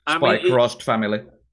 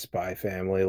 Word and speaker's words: Spy x Family: I think the literal way you pronounce it is just Spy Family, like Hunter Hunter, but people prefer to say it with the X. Just don't say it with the cross.